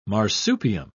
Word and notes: marsupium: British English

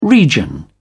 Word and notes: region: British English

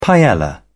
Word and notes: paella: British English

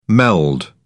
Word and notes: meld: British English

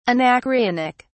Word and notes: anacreontic: British English

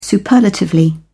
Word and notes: superlatively: British English

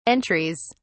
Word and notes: entries: British English

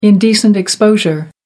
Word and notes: indecent exposure: American English

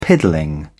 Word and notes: piddling: British English

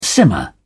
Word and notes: simmer down: British English

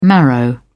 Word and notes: marrow: British English

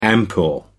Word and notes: ampoule: American English

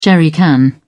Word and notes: jerrycan: British English

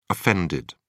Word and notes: offended: British English

